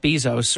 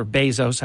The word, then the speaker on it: Bezos: Don't forget, they had absolutely no problem when the second richest man in the world, Jeff Bezos, or Bezos, however you pronounce his name, I hear it both ways, when he purchased the Washington Post, this was democracy, this was fantastic, nothing negative.